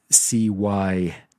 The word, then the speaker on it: Sivas: Why am I typing I'm misspelling his name to pronounce it properly. It's Cyves.